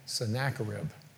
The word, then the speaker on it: Sennacherib: including King Sennacherib, which is the way you pronounce that, by the way, in the 8th century B.C.